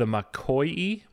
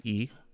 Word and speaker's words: Makoi: it is the Makoi. I found a phonetic spelling of it. Makoi. Makoyii.